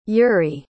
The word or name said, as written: urey